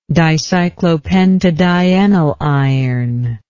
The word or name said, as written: dicyclopentadienyliron